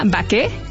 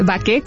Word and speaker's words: Baquet: Se llama Baquet, que viene del anglicismo bake, pero como bien dices, es una panadería francesa con corazón mexicano. Entonces, es Baquet con acento en la E.